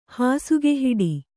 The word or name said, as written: ಹಾಸುಗೆಹಿಡಿ